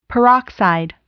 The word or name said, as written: peroxide